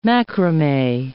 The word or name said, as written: macrame